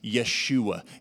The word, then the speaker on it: Yeshua: The prepositional phrase, in your salvation, is literally pronounced Yeshua.